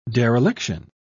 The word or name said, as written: dereliction